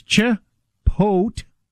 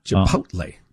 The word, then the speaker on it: Chipotle: So it's Chipotle. That's the emphasis is on, the emphasis is on pote. – Chipotle.